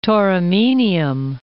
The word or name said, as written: Tauromenium